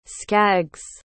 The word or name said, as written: skaggs